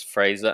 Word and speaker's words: Frazer: So as previously mentioned, my name is Frazer, and that is with a Z, F-R-A-Z-E-R, because you can spell it with an S, or you can also spell it with an I in there, like I think there's an American sitcom, Frazer. But it's F-R-A-Z-E-R.